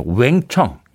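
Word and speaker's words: Wang Chung: 아, 왕청. 미국 AFKN, 지금은 AFN인데요. 어, 당시에는 AFKN이라고 불렸던 라디오 방송사에서는 네, 웽청 이렇게 발음했던 기억이 납니다. 그 발음을 얼마나 따라했던지 웽청입니다.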